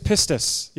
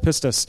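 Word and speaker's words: pistis (πίστις): The Greek word for faith is pistis. Yes, I know it sounds aggressive. That's how you pronounce it. It's a Greek word, pistis.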